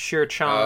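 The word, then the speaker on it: Shi Qiang: According to the audiobook I listened to, his name is pronounced Shir Chong, oh, okay.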